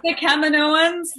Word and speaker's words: Kaminoans: And just a quick one, in your opinion, is it Kaminoans or Kaminoans? – Oh my gosh, I don't want to get bad fan mail. I say Kaminoans. That's how Dave Filoni pronounces it. – Oh, okay. – He changed his mind. I don't know. But I say Kaminoans. It could be tomato, tomato. Mm-hmm. But I pronounce it Kaminoans.